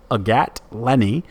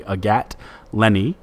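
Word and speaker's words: Agathe Laisné: On the women's side, former 2019 WGCA first team All-American Texas Longhorn Agat Lenny, you may remember that in print as AGATHELAISNE. That's pronounced correctly. Agat Lenny also punched her first time professional tour card, the LPGA, this week.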